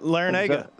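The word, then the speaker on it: Larrañaga: I like to see that the Miami, because I think I don't know how to say his last name, Jim Laranga. – Yeah, Laranaga. Yeah.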